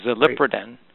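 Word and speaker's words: xaliproden: It's pronounced like a Z, but it's an XALIPR. ODEN, so zilipridin.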